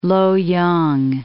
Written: Loyang

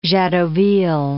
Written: Jadotville

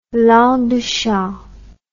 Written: langue de chat

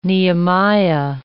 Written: Nehemiah

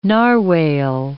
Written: narwhale

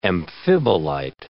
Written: amphibolite

